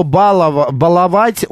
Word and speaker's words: баловать: По поводу слова «баловать», ударение на третий слог на букву «А». «Баловать». Вот, вот как правильно ставится ударение.